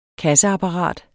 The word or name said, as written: KASSEAPPARAT